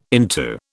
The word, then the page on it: into: British English